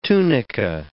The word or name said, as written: tunica